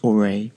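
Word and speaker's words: Aurae: I think it's pronounced Auray, spelled A-U-R-A-E, E at the end with the accent aigu on the E.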